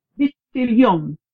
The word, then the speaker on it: Midtjylland: Midtjylland. – Midtjylland.